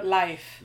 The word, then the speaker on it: Leif: Back to Leif Erikson, I think. Yes. I said that right, didn't I? Yeah. Okay. No, life. Life? I thought I said yeah. no. I thought I said that. You said Leif.